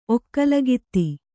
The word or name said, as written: ಒಕ್ಕಲುಗಿತ್ತಿ